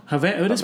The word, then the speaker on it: Havana: I think I read somewhere that when your grandmother took you in, she took you to, is it pronounced Havana, not Havana? – Havana.